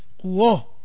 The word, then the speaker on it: quo: Cuando entonces nosotros vamos a utilizar, es invariable el plural, los status quo, se debe pronunciar quo, con una tildar en la O, digamos, quo, ¿eh? Por más que no se escriba esa tilde.